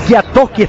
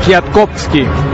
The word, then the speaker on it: Kwiatkowski: Bueno, Tomás Kwiatkowski, está bien pronunciado así, Daniel. Cuarto, no, el VAR, en realidad, polaco es el que va a estar hoy en el VAR. Tomás Kwiatkowski.